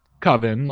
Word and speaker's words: Coven: which is spelled and should be pronounced Coven, like a coven of witches, but they get it wrong and they commit to it even though they're corrected multiple times.